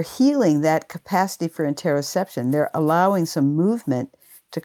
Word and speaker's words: interoception: Interoception. – Interoception.